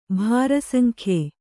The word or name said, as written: ಬಾರಸಂಗೆಯ್